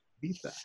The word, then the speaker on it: Ibiza: So it actually sold to an end user because there's actually a very popular club in Ibiza, I guess if you pronounce it correctly. Ibiza, and called Hi Club, and they were the purchaser.